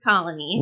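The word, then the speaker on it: Koloni: It's K-O-L-O-N-I, but it's pronounced Colony.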